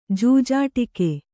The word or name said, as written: ಜೂಜಾಟಿಕೆ